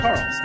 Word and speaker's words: Carl: My name's Carl. – That's Carl, spelled with a C.